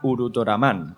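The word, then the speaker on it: Urutoraman (ウルトラマン): Aunque en silabario o katakana se pronuncia Urutoraman, como bien sabe nuestra intérprete Chachi Achi.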